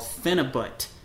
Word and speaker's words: Phenibut: So the Russians invented this compound called Phenibut. Phenibut. – Phenibut. – Phenibut. – I remember talking about this with you guys. – Yeah. It starts with the P. It's spelled P-H-E-N-I-B-U-T. Phenibut.